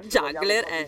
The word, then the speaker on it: Juggler: Juggler. La pronuncia corretta.